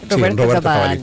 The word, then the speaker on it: Roberto Cavalli: Pero este vestido fue confeccionado por la firma italiana de Roberto Cavalli. ¿Sí se dice así? – Roberto Cavalli, tal cual.